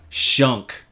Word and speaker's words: Shunq: That was Nerve Cell with their song Shunk. Shunk's fun to say. You know why? – Rhymes with chunk.